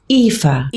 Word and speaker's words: Aoife: But the names, some of the people's names, I was talking to someone whose daughter's name is Aoife, and it's spelled A-O-I-F-E. – Yeah, Celtic, yeah, in the Irish. – It's like Eve. Yeah. It's like the name Eve. It's Aoife. – Aoife.